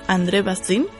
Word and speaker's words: André Bazin: Y esta revista fue fundada en 1951 por André Bazin, creo que se pronuncia así.